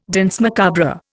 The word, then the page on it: danse macabre: Indian